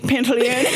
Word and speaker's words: Pantoliano: Joe Pantoliano. Is that how you say his name? – Pantoliano.